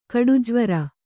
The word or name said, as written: ಕಡುಜ್ವರ